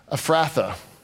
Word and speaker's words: Ephrathah: Ephrathah.